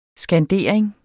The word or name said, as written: skandering